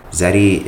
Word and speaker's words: Xeddy: So please, tell us a bit about what Xeddy is. Am I saying it correctly? And how can we learn and support what you're doing? – Yeah. So you are saying it correctly. Xeddy is a rewards platform steered for college students to get the most out of their local ecosystem.